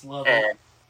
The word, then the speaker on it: Lovett: It's pronounced Love It. – Love It. Okay.